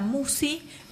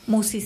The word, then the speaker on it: Musci: El canal lleva mi nombre, Marisa Musi. Musi se escribe M-U-S-C-I latina.